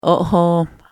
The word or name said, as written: ohooma